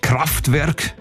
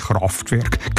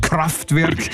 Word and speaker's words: Kraftwerk: Kraftwerk, nicht Kraftwerk, Kraftwerk. – Entschuldigung. – Ich habe es jetzt falsch gesagt.